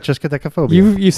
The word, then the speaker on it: triskaidekaphobia: Triskaidekaphobia. – That's right. Triskaidekaphobia. – So you've heard that. – Yeah, and am I pronouncing that correctly, yeah, Mr. Triskaidekaphobia. – Triskaidekaphobia. – Right.